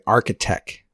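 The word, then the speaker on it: Architeq: So next week, we're going to talk about a artist that goes by Architech, spelled with a Q at the end, E-Q, Architech.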